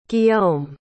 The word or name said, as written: guillaume